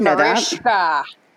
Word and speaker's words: Mariska: It's Mariska. – There's a silent H in there.